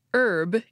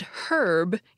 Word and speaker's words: herb: For example, the word for a certain kind of plant is pronounced herb in American English and herb in British English.